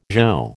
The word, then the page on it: zho: American English